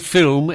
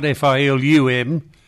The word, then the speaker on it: film: I said that word film, F-I-L-M, has not got a U in it. It's not F-I-L-U-M, it's film. – Oh, thank you. Yeah, always correct me. – Film, that's how you say it.